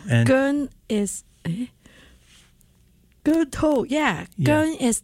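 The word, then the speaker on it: Keung To: Gun To. – Gun To, okay. And I think, I'm still trying to say it right, but Gun To, that's spelled K-E-U-N-G.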